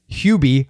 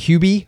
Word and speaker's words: Hubie: And it is Hubie. I say it's right. That's how I pronounce it. Hubie Halloween.